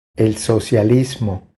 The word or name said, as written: El socialismo